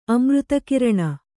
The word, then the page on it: ಅಮೃತಕಿರಣ: amřtakiraṇa